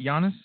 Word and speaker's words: Giannis: So the Giannis and – am I saying that right, Giannis? – Yes.